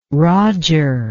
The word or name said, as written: rodger